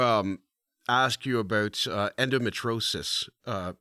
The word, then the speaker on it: endometriosis: So you you have spoken about your life with endometriosis. I think I said it right yes, that time. Endometriosis, yes.